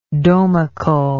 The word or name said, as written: domically